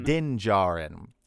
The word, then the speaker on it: Din Djarin: Din Djarin.